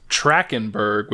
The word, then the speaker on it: Trachtenberg: And he also said his name is pronounced Trachtenberg without the T in it, actually, which I had no idea.